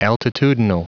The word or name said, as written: altitudinal